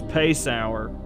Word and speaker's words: Paysour: Now, I'm trying to decide whether it's Paceauer or Paceauer. I'm going to take your phonetics for truth that it's Paceauer.